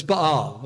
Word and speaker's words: Baal: I know we say Baal, but I have to look up some of these words. Even though I took Hebrew, I have to look up and say, how do you really pronounce this? Because you get into saying it one way, but it's Baal, okay?